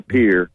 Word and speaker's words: Pierre: Pierre is the capital. That's how they pronounce it up there, Pierre.